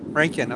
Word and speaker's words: Rankin: Rankin, okay.